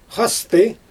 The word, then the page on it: xastɯ: Xyzyl